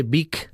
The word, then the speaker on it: Vic: Pero siempre también quiero preguntarte, María Ángels, porque la propia toponimia, eso de Vic, ¿eh?, que es, bueno, se escribe V-I-C, Vic, como suena, en realidad, sí, la toponimia, ¿de dónde viene?